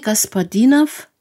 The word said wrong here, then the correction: Gospodinov: Ich wiederhole nochmal den Namen des Autors, denn ich habe ihn mit Betonung ausgesprochen. Er heißt Gospodinov.